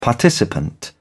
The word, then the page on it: participant: British English